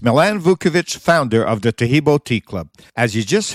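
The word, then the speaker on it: Taheebo: Taheb is spelled T-A-H-E-E-B, like boy, O, then the word tea, and then the word club.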